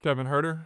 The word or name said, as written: Kevin Huerter